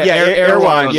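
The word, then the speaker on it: Erewhon: Is that how you say it, Air One? – Yeah, Air One.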